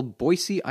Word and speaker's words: Boise: I went to beautiful Boise, Idaho, and I've been educated.